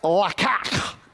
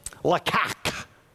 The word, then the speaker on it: laqach: And so the one word for the phrase, and God took him, is this word spelled L-A-Q-A-C-H, and it's pronounced lakach. Because when you're speaking Hebrew, you got to do the lakak. Literally, I listened to the pronunciation like a dozen times. Lakak.